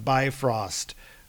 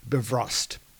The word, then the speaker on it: Bifrost: We have the Bifrost, or sorry, we have to pronounce it properly because Robert is listening, Bifrost.